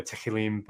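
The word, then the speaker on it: Tehillim (תהלים): I call the Psalms the prophet Telem, which I'm probably saying it wrong. Sorry, guys that do Hebrew. There's an extra syllable kind of hidden in there, Tehillim, but I can't say it properly.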